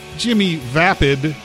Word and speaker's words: Jimmy Vapid: Jimmy Vapid is how it's pronounced.